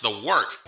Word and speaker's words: wort: It is the wort, not warts, not forts, not short, the wort.